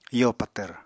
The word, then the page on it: yo patvr: apatani